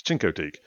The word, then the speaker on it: Chincoteague: It's Chincoteague. I don't have a shin. I have a chin.